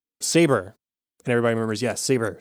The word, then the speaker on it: Sabre: Perhaps the funniest moment for me in the whole episode is, you know, the cold open, they refer to the company's new owners as Sabre, spelled S-A-B-R-E. And we don't know what's wrong until Gabe shows up and pronounces it correctly, Saber.